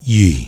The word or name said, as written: Yi